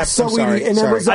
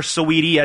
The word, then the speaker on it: Saweetie: How was the Sweetie concert? – Sweetie. Sweetie, not Sweetie. Sweetie. Bay Area's own. – Is that how you pronounce it? – Yeah, Saweetie.